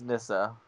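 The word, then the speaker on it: Nyssa: And when she turns around, none other than how would you pronounce it? Naissa? Nissa? – Think it's Nissa.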